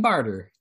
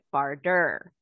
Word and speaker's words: Bardur: Okay, but I'm going to have to take issue with how you pronounce the tribe name, because that is not the way I say it. I say it Barder. – I say Barder too, right? B-A-R-D-U-R.